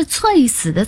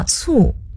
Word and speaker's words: 猝: 那个字不是粹死的粹吗？右边也是卒，但是左边是反犬旁。 – 那个字读作猝，猝死。